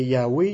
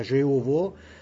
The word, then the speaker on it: Yahweh: mais la prononciation c'est Yahweh, il y en a quand ils disent c'est Jéhovah, mais ça, c'est pas Jéhovah parce que la prononciation J existait même pas. C'est rien que depuis 400 ans que la prononciation J existe. Ça serait Jéhovah, mais c'était plus Yahweh, qui était la vraie prononciation.